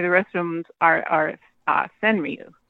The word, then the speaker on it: Senryu: How do you say Senryu? – Senryu? Is that how you say it? – Yeah, that's how I say it. Senryu.